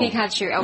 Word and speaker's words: Pikachu: Pikachu, okay, yeah.